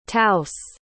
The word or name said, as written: taos